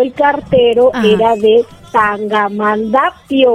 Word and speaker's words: Tangamandapio: Tangamandapio. – Ah, mira, este es un trabalenguas, Tangamandapio, por eso no lo dije bien.